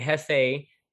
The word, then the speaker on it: Jefe: And so you can find me on, you can go to my website, which is Jefe Living, and Jefe is spelled J-E-F-E.